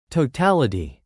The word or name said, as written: totality